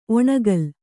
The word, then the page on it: ಒಣಕಲು: oṇakalu